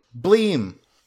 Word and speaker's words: Bleem!: And Bleem, Bleem is spelled B L E E M with an exclamation mark at the end always.